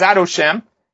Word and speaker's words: Adoshem: We say Yudke Vavke, and we pronounce it as Adoshem.